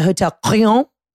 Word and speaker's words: Hotel Crillon: But the Rosewood Paris, I think it's the Hotel Crillon. That's how you pronounce it. Every time we get in a cab, we'd be like, can you take us to the Hotel Clion? And they'd be like, Clion? Yes, that's it.